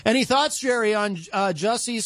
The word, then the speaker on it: Jussie: Jussie Smollett, who is just he was arraigned in a Chicago court for his fake hate crime incident.